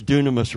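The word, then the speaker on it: dunamis (δύναμις): Power and, and we, of course, dunamis rhymes with dynamo, and dynamo rhymes with dynamite.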